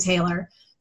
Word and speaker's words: Taylor: It's just at Marilynn Taylor.